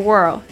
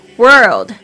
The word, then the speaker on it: world: It is a，就是呢，这是个 big and beautiful world，两个都是 B 开头的，big 就是大的，beautiful 就是美丽的。 World 这个字呢，稍微注意一下它的发音。 World 好像那个舌头呢卷起来又翻出去那种声音，这个哦的声音一定要发出来。